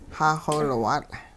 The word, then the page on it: xaaxowilwaatL': hupa